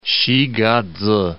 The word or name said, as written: Xigaze